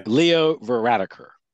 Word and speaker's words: Leo Varadkar: Leo Varadkar.